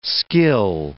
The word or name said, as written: skill